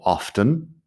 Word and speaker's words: often: Tengo un vídeo en algún sitio sobre la pronunciación de often, que hay algunas personas que si pronuncian la T en often, dirían algo así como often. yo nunca he pronunciado la T. Las dos formas se consideran correctas, solo que yo lo digo sin pronunciar la T.